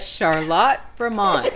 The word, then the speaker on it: Charlotte: We're at Charlotte, Vermont.